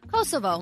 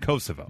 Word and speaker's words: Kosovo: Kosovo. – Kosovo. – Yeah. – Okay, great. Kosovo.